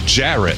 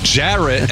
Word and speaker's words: Jarrett: Ferret does rhyme with Jarrett, but my name is Jarrett. Jarrett.